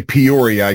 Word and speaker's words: Peoria: We say Peoria, I guess, because we're from out of town and we don't know any better. But is it Peoria or something like that? – You said it right.